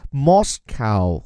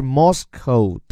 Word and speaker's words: Moscow: 给我们坚持晨读打卡已经第三周的小伙伴提个醒，特别是后面这个 OW。你既可以把它发音叫做 o，也可以发音叫做 ow，所以 Moscow 或者是 Moscow 都是可以的，看你喜欢哪一个。